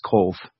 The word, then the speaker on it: Cobh: C-O-B-H. That's how it's spelt. It's pronounced Cove.